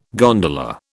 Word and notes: gondola: British English

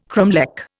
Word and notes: cromlech: Indian

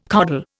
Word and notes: coddle: Indian

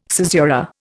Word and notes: caesura: Indian

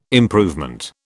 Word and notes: improvement: British English

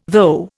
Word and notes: though: Indian

